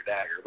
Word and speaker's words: Dagger: Dagger does, it is mad because I'm mad because my name rhymes with Swagger, Dagger.